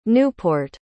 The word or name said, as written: Newport